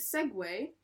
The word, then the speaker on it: segue: You pronounce it Segway. – Wow. – But it's spelled Segu.